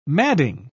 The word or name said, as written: madding